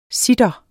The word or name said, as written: citarer